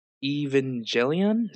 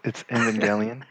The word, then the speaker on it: Evangelion: And then been talking to me about Evangelion. Is that how you say it? – Excuse me. No, it's Evangelion.